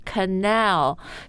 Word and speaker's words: canal: It's on the second syllable. Canal. – Yeah. Is canal 还是 canal? – Canal. – Canal.、Like Al, 对吧 Al, like the name Al.